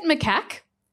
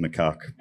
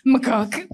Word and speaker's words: macaque: All of a sudden, the pet macaque owned by the steward of the grapevine. – I think it's actually pronounced macaque, but... – macaque.